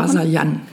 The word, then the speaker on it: Rasayan: Das heißt, wenn du jetzt eine Satnam Rasayam, habe ich das richtig ausgesprochen? – Rasayan.